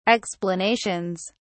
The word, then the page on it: explanations: British English